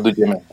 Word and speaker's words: Duchement: Pablo Duchement. – Vale, pero no se pronuncia en francés. – Eh, estamos en España, ah, vale. – así decimos Duchement.